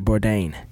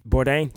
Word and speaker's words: Bourdain: I still didn't say it right. Bourdain. Bourdain? Yes, got it right the second time.